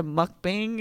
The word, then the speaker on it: mukbang: I pretty much had a mukbang, is how you pronounce it, by myself, listening to Carrie Underwood sing the sad love songs.